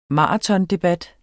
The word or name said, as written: maratondebat